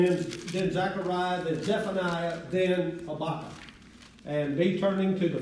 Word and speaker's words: Habakkuk: The book of Habakkuk. Now, some people pronounce it Habakkuk, but I pronounce it Habakkuk.